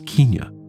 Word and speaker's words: Kenya: In 1888, the East Africa Protectorate is established, known later as the Colony of Kenya, pronounced in those days as Kenya.